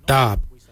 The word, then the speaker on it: dhaab: Today we will look at, for knife we say dab. Dab is spelled D-H-A-A-B.